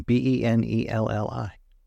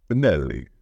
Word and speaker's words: Benelli: It's Italian. Benelli. – Benelli.